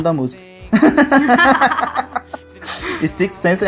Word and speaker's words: Sixth Sense: Six Sense é a música. – e eu de pronúncia difícil falar Sixth Sense. Sixth Sense.